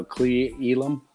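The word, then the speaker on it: Cle Elum: Am I saying that right? – Yeah, I guess so. Clay Elum. Yeah, it's like two words, right? – It's got a dash over it.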